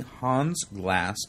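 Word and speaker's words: Hans Glas: They bought the struggling Hans Glass Automotive Company, which included two just like it's spelled, H-A-N-S-G-L-A-S-S.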